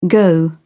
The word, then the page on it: go out: British English